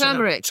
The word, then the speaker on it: turmeric: Yeah, well, I'm going to that and turmeric. Is that how I pronounce it? – Turmeric.